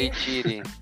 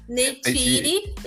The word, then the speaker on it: Neytiri: Neytiri. Neytiri,